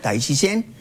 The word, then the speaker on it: Tai Chi Quan: El nombre completo es se escribe Tai Chi Quan, se pronuncia Tai Chi Shen.